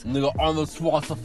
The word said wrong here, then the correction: Schwarzenegger: I don't know how to pronounce his surname. – Schwarzenegger. – I should be embarrassed because he's been in our Schwarzenegger. That's how he fucking pronounces his name.